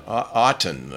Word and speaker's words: Auton: Is that how you'd pronounce it? – Yeah. – It's Auto with an N. – Yeah, A-U-T-O-N. Auton, I guess.